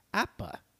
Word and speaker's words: APBA: Tony had familiarity with the game as he pronounced it correctly as APBA.